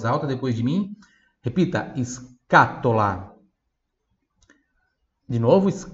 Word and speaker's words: scatola: Então, por exemplo, começando com A, SC seguido de A, a pronúncia fica igual da língua portuguesa. Então, por exemplo, escátola, né? Que é caixa, escátola, né? Se escreve S-C-A-T-O-L-A, escátola, escátola.